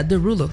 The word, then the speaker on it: Desrouleaux: Entonces se escribe Desrouleaux, pero se pronuncia Derulo, digamos.